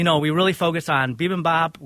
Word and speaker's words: bibimbap: Is that the right way to say it? Bibimbap? – Bibimbap. Yep.